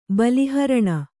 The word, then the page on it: ಬಲಿಹರಣ: bali haraṇa